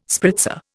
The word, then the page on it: spritzer: Indian